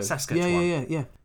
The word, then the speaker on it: Saskatchewan: Saskatchewan. – Yeah. It's like Sasquatch, isn't it? Like the Yeti.